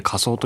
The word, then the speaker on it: 花僧: 一人の男がおります、はい、花の僧と書いて花僧と読むんですが、